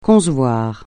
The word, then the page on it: concevoir: francais